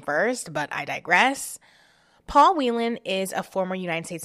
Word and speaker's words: Whelan: Now, I've heard Whelan and Whelan. I'm going to say Whelan because it's spelled W-H-E-L-A-N.